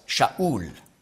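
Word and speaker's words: Sha'ul (שאול): Und werden sehen, der Name Saul, auf Hebräisch spricht man aus Sha'ul.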